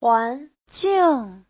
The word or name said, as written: huan2jing4